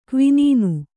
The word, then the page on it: ಕ್ವಿನೀನು: Kannada